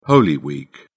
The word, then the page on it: holy week: British English